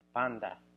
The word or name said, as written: panda